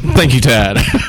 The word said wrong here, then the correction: Thad: Tad. Oh, sorry. It's Thad? Thad. I think I said it right now. Thad.